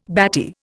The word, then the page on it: batty: Indian